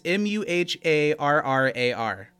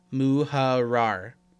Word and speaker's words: Muharrar: It's M U H A R R A R. How would you say it? Muharrar. – Muharrar. – This is double R, so you got to roll it.